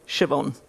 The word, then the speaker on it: Siobhan: So Siobhan will just be S H E V hyphen E N, Siobhan. So easy, right? It's not how she spells it, it doesn't sound that way, but phonetically spelling I think is really important.